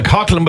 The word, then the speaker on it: Coughlan: I think it's Nicola, I can't really pronounce her second name, I think it's Coughlin, C-O-U-G-H-L-A-N. – You're spelling it absolutely right. We say Nicola Coughlin, but that's absolutely who it is I'm thinking of.